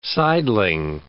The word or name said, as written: sideling